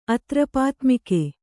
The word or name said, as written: ಅತ್ರಪಾತ್ಮಿಕೆ